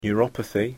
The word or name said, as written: neuropathy